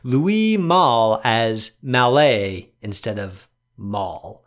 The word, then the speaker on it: Louis Malle: So Louis Malle is the correct pronunciation.